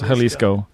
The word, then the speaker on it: Jalisco: I think it's pronounced Jalisco.